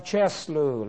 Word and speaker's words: Chisleu: that's the month of Cheslu. Let me spell that. C-H-I-S-L-E-U. Cheslu.